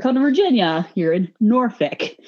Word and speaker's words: Norfolk: It's Norfolk.